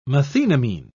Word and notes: methenamine: British English

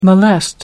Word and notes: molest: American English

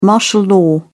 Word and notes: martial law: British English

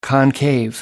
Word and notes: concave: American English

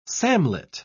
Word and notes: samlet: British English

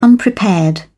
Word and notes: unprepared: British English

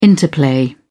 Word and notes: interplay: British English

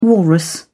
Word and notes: walrus: British English

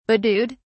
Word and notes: bedewed: British English

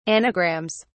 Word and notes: anagrams: British English

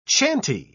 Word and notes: chanty: British English

